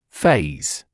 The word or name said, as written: phase